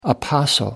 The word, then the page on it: apostle: American English